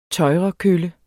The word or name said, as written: tøjrekølle